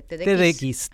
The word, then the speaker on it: TEDx: TEDx.